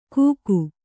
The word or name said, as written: ಗೂಕು